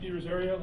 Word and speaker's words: DiRosario: My name is Nick DiRosario. Last name is spelled D-I-R-O-S-A-R-I-O.